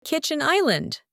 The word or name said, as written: kitchen island